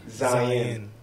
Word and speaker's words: Zion: Zion. You know people say my name wrong a lot. – Oh, do that. – Zion. It's like Zion. Lion with a Z.